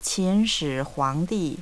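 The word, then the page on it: qinshihuangdi: language Chinese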